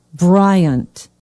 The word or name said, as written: bryant